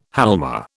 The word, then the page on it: halma: British English